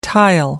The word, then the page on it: tile: American English